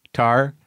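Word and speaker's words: Tár: So, you know, I watched this movie, Tar.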